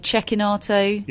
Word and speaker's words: Cecchinato: Cecchinato is actually Cecchinato. – Yeah, I checked with an ATP employee on that one, and they backed me up, so I feel really let down.